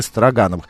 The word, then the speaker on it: Строгановы: Друзья, 23 августа открытие Всероссийского, э, значит, во Всероссийском музее декоративного искусства э, стартует выставка «Художественное наследие Строгановых». Мы можем разыграть два билета на эту выставку. А, Строгановы, да, так читается, Строгановых, извините, ради бога.